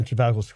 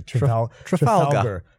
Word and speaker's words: Trafalgar: They are everywhere in Trafalgar Square, though, right? – Yeah. – I'm not even pronouncing that correctly. – Trafalgar.